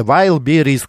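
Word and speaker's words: Wildberries: Вайлберис, во-первых, произносится Вайлберис, как вы и написали.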